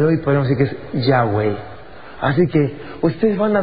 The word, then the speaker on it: Yahweh: Y salía sonando algo como Yahweh, o ya, en griego, en español, Yahweh, si lo pronunciamos en español. En inglés suena como Yahová.